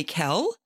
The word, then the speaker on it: Kel: So people will call me Kel, but do you spell that K-E-L? Do you spell it K-E-L-L, which looks like kill? – Right.